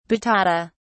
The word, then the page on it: batata: British English